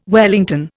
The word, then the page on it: wellington: Indian